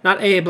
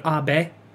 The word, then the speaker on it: Abe: Not Abe like Abe Lincoln, but Abe. Abe, it's two syllables, Abe.